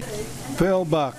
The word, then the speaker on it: Phil Buck: Phil Buck, P-H-I-L-B-U-C-K.